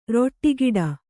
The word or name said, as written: ರಟ್ಟುಕಟ್ಟು